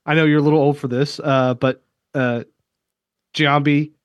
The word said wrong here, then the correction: Jumanji: You're not saying Jumanji, are you? – Jumanji.